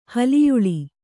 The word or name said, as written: ಹಲಿಯುಳಿ